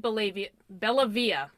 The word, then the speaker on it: Bellavia: you can head over to David Bellavia, B-E-L-L-A-V-I-A, right?